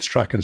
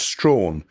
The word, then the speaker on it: Strachan: And if you start reading this, I'm guessing most of the readers will start off with Strachan syndrome. And it's only when they get to the discussion do they mention that Dr. Henry Strachan pronounced Strawn. So suddenly you feel everything you've read to date is sort of gone slightly wrong, because actually it's all been Strawn all the way through.